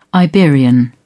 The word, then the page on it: Iberian: British English